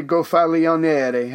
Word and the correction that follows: Gonfaloniere: How do we say that? The leader of the city. Gonf, Gonfaloniere. – Gonfaloniere. Yeah. yeah.